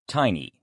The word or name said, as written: tiny